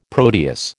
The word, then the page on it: Proteus: American English